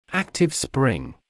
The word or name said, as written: active spring